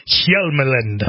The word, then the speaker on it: Hjelmeland: After being refused entry into the strip club for belligerent behavior and racist comments earlier in the evening, Thomas Elliot Kjelmeland... Sorry, what I just it? Like that. I like that name because it's spelled H-J-E-L-M-E-L-A-N-D. Kjelmeland.